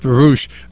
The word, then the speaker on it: Baruch: Baruch, meaning blessed in Hebrew, is how the family pronounced the the name many years ago, but the family converted to Protestantism, and then I converted further to Catholicism, and we pronounce it Baruch.